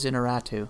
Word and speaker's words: Iñárritu: A guy I used to work with was an extra in that film and said he at one point got to meet Iñárritu, is that his name? Is that how I pronounce it? – Alejandro Gonzalez Iñárritu.